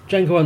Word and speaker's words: Django: The D is silent. – Django Unchained, 2012 American Western.